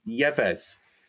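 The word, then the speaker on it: Yves: The dudes on comms pronounce Eve Basuma Yeves.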